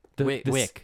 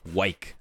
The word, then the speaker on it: Wyc: I don't know actually how to say his name. Weiss Grausbeck? – Wick. – Wick. Damn, I whiffed on that one. Wow. – Wyc. W-Y-C.